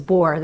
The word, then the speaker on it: Bohr: And just so people understand, his last name is Bohr. That doesn't mean he's boring. And it's spelled B-O-H-R? – B-O-H-R, that's correct. Okay. Not B-O-R-E.